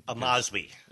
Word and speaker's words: Amazwi: And I appreciate you pronouncing it correctly. – Amazwi. – Yes, thank you.